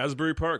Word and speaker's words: Asbury Park: Back to the girlfriend who had a condo looking over Asbury Park. Did I say that right? – Asbury? Yeah. – Asbury Park, that's it, yeah.